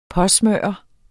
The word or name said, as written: påsmøre